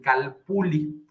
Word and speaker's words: calpulli: que es el término del calpuli, aunque se escribe calpulli. Eh, los, este, los antiguos mexicanos siempre la manejaban no como una L, sino como una L extendida, como pudiera ser en el idioma italiano, y este calpuli significa gente de linaje antiguo, significa gente de barrio conocido.